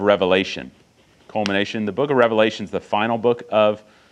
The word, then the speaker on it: Revelation: And just so you know, it's pronounced Revelation because there's only one Revelation. Revelations, is the final book of the New Testament that records the prophetic vision that was given by Christ to the Apostle John.